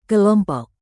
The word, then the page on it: kelompok: Indonesian